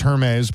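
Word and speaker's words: Hermès: Pretty sure that's pronounced Hermes, but go on.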